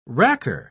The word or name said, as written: racker